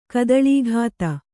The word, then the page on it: ಕದಳೀಘಾತ: kadaḷīghāta